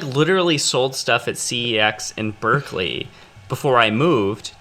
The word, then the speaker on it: CeX: Is that how you say it? – It's CEX. CEX.